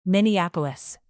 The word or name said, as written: minneapolis